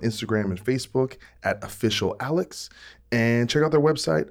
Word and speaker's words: Alyxx: This War by the band Alex. And just to let you know, that is spelled A-L-Y-X-X.